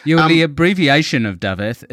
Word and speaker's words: Dafydd: He moved over here, and Dafydd uh, is the Welsh version of David, but um, the F is pronounced like a V, and the double D's are pronounced as a T.